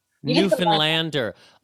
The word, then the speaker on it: Newfoundlander: You say, like, understand. So you're like a Newfoundlander. – Newfoundlander. Okay. So it's not found. It's fund. – Exactly.